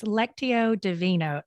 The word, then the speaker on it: Lectio Divina: And my husband has been engaging, but it's Lectio Divina. Yeah. Did I say it correctly? – You did. Yes.